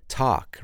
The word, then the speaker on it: talk: Or the silent L, talk. Remember, we don't say talk, we say talk.